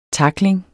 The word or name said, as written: takling